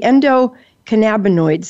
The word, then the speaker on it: endocannabinoid: but it's the endocannabinoid system.